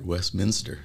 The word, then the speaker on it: Westminster: Yeah, you even said it right, Westminster.